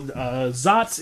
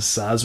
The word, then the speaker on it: Saaz: It's spelled S-A-A-Z, so you may hear it pronounced as Zaz, but most people pronounce it as Zaz, which I believe is the German pronunciation.